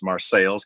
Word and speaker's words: Marseilles: But that's just me in front of the the ancestral Morello home in Marseilles, Illinois, uh, spelled like Marseille, France, pronounced Marseilles because it's in central Illinois.